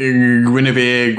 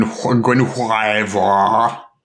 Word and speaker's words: Guenhwyvar: Uh, Guinevere Gwen Guenhwyvar, as, as it's correctly pronounced.